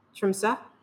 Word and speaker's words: Tromsø: Tromsø.